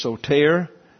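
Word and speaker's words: soter (σωτήρ): And over here is soter, emphasis again on the second syllable. The eta in Greek has a long A sound when it's pronounced, so it's soter.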